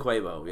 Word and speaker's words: Quavo: Might be Quavo, might be Quavo. Quavo, yeah.